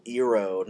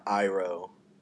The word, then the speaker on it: Iroh: And then didn't they mispronounce Uncle's name? – Oh, yes. That one bugged me, too. They pronounced it Uncle Iroh, not Iroh.